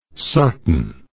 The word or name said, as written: sarton